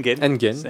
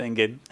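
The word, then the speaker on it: Enghien: Il y a E-N-G-H-I-E-N, e vous ferez vous-même votre prononciation. C'est un lycée expérimental. Non, c'est Engen. Engen.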